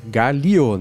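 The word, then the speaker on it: gálio: Ou galho, né? Que é aquele metal que derrete alumínio também. – É, galio, né? Não é galho.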